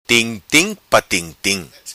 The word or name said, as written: Tim tim pa tim tim